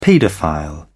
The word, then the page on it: paedophile: British English